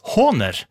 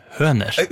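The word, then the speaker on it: Hohner: Håner er en tysk produsent av trekspill, munnspill og andre instrumenter, uttales Håner, og ikke med omlyd høner.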